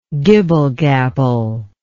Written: gibble-gabbler

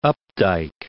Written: Updike

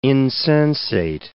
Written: insensate

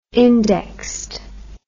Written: indexed

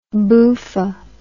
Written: boofa

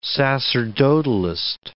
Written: sacerdotalist

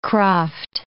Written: kraft

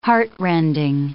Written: heartrending

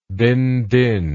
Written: binh dinh

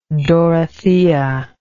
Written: dorothea

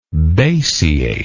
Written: basiate

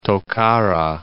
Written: Tokara Islands

